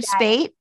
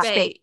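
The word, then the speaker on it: Speight: Richard Spite. – Richard Spate. Mm-hmm. – Yeah. Spate. Yeah.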